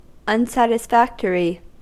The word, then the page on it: unsatisfactory: American English